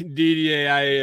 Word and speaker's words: Didier: Anyway, Didier, I did I say that right this time? – You did.